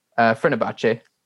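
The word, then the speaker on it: Fenerbahçe: Valencia, AC Milan, Marseille, and Fenerbahce. Is that how you pronounce it? – Fenerbahce.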